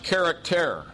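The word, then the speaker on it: charaktēr: And it's pronounced character. It's where we get our word character.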